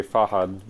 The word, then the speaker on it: Fahad: Yeah, so yeah, as you said, so my traditional pronunciation, my name is actually Fahad with a softer D, more like a TH at the end.